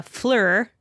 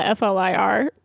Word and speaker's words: FLIR: Which I don't blame them, because it's like F-L-I-R. And it's like, yeah, what am I supposed to do with this? – I feel like, I just call it F-L-I-R.